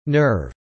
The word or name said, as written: nerve